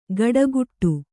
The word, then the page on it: ಕಟಗುಟ್ಟು: kaṭaguṭṭu